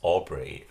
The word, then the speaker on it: Aubrey: My name's Aubrey. That's with a B, not a D. – Aubrey as in strawberry. – Yeah, rhymes with strawberry.